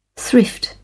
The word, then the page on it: thrift: British English